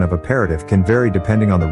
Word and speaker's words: aperitif: Aperitif is pronounced as aperitif. It is a French word that is commonly used to describe a drink that is consumed before a meal to stimulate the appetite.